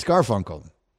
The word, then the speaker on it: Garfunkel: Well, you have put the emphasis on the wrong syllable. – It's Garfunkel? – It's Garfunkel. It's Simon and Garfunkel.